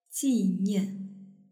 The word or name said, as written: jinian